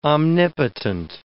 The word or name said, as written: omnipotent